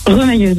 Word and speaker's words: remailleuse: C'est ce que c'est. – Remailleuse. – Ça se prononce pas comme ça. Remailleuse. – Remailleuse, oui. – Ah, remailleuse, pardon.